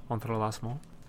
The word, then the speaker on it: entrelacement: It would be something like that. Entrelacement.